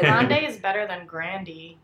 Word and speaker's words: Grande: This is totally off topic, but I learned that we've all been pronouncing Ariana Grande's name wrong. – What is it? – It's Grandee. She says Grandee, and she's just never corrected anyone.